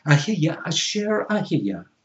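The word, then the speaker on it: Ehyeh Asher Ehyeh: or in Hebrew, the I Am that I Am, is pronounced Ahiya Asher Ahiya.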